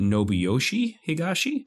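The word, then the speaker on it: Nobuyoshi Higashi: Am I saying this right? Nobuyoshi Higashi? Yeah, that's correct. You nailed it.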